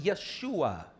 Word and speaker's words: Yeshua: Because in the Hebrew language, there is no J sound. So if they were to pronounce the word Jesus, it would be Yeshua.